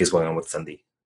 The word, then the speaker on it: sandhi: And um, sam D becomes Sandhi because an N is more e- is easier to pronounce in front of the D. D. They're both pronounced with your tongue against your teeth.